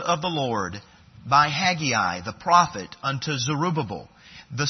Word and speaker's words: Haggai: Haggai, or you could say Haggai is also another way of pronouncing it.